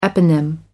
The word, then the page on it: eponym: American English